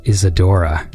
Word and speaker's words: Isadora: We're going to listen to a song I liked from it called Isadora, because I like this one.